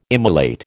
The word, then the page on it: immolate: American English